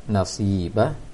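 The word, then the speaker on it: Nasibah (ناصبة): Sangat jelas tadi bacaan pada ayat kedua yang baca tadi, Amilatun Nasibah, seharusnya na yang panjang karena ada alif setelah na, dan si tidak ada ya, tidak boleh panjang, yang dibaca oleh Zahra tadi.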